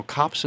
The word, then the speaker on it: Copts: Now, Copts is spelled C-O-P-T-S. It's a little challenging to pronounce those syllables, those consonants, right, all together. Copts. Voice of the Copts.